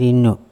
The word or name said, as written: ino